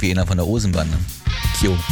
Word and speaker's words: Keo: Die Band wird K-E-O geschrieben. Ich dachte irgendwie K.O. oder wie auch immer, aber anscheinend werden sie K.O. ausgesprochen, leitet sich vom irischen Nachnamen der Brüder ab.